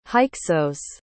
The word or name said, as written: hyksos